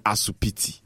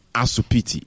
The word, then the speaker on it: asupiti: But if you can take a second, you know, pause and think about why we say asupiti. – Is there a reason? Not hospital. It's because we couldn't pronounce hospital. It's as simple as that. And so, forefathers back in the days in the Gold Coast era, you know, they were saying asupiti instead of hospital.